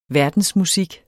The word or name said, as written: verdensmusik